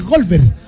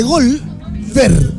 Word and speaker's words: Golver: Uno se escribe Goldberg, tal cual, y el otro es Golver, ¿cachai? O sea, G-O-L de Golver, con R, ¿ah? Golver y Goldberg.